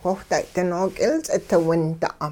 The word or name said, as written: whiwhday-ding nook'ilts'it winda'